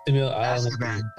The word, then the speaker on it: Azkaban: Harry Potter 3 and the Prisoner of Azkaban. – I think I said that right. – Did I say that right? Azkaban. – Azkaban.